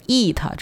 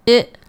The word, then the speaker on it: it: 接下来 it 动物它这个单词不要读成 it 或者 eat，这都是错的啊，字母 i 它发的是一个 it it 的音啊，是非常短促干脆有力的啊，从这个嗓子眼里弹出来的这么一个 it it it 啊，你如果读成 e 或者 a 的话就会读成 eat eight，这种声音听起来就非常拖沓啊，就不对啊，给它放的再快一点，读的再有力量一点 it it，不要读成 eat eight 啊。